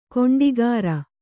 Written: ಗೂಂಡಾಗಿರಿ

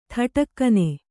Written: ಥಟಕ್ಕನೆ

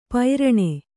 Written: ಪೈರಣೆ

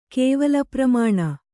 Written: ಕೇವಲಪ್ರಮಾಣ

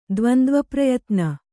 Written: ದ್ವಂದ್ವಪ್ರಯತ್ನ

